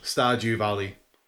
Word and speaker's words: Stardew Valley: It's called Stardew Valley. – Okay. – As in with a D. It's S-T-A-R-D-E.